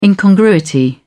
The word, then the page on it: incongruity: British English